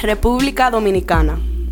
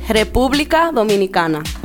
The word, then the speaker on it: República Dominicana: República Dominicana. – Say it again? – República Dominicana.